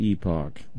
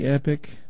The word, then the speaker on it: Epoch: It's spelled E-P-O-C-H. I'm just choosing to pronounce it Epoch. If you say epic, I will disagree with you and call it Epoch.